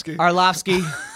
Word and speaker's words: Arlovski: Did I just butcher that? – Arlovski.